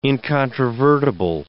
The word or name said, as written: incontrovertible